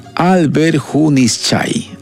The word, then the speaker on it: Alberjunichay: Alberjunichay. ¿Está bien? ¿Lo pronuncié bien? Alberjunichay. Así es, con Estudiantina Ayacucho.